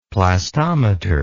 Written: plastometer

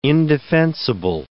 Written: indefensible

